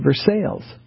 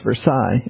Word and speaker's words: Versailles: And so I talked about the Treaty of Versailles. And Mr. Mason, laid me out. He said, it's pronounced Versailles.